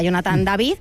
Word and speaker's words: Jonathan David: Y así que, en cuanto a la pronunciación de su nombre, la primera duda que nos surge es, atendiendo a sus orígenes francófonos, pues sería Jonathan David, aunque en Norteamérica sería David.